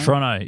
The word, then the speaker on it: Toronto: Toronto, yeah. Fuck that second T, brother. – Yeah, okay, sweet. Toronto? – Toronto.